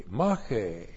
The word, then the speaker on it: mache: And from the outside, we have mache. M-A-C-H, long E, mache.